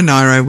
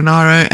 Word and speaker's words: Nyro: Now, you pronounce it Nyro. I pronounce it Nero. Is there. If there is a correct pronunciation of this? – There is, and I can't remember which one it is. I think it might be Nero.